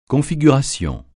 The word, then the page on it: configuration: francais